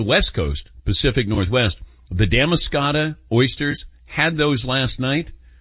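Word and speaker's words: Damariscotta: Yes, Damascata is the correct pronunciation.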